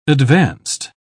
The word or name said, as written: advanced